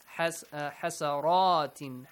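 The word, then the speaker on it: حسرات: Hasaratin. Hasara. Ra is a heavy letter.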